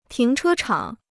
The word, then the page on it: 停车场: tíng chē chǎng